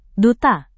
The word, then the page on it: duta: indonesian